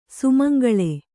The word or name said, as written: ಸುಮಂಗಲಿ